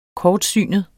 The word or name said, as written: kortsynet